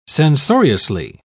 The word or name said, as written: censoriously